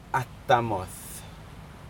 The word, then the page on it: atamod: Aneityum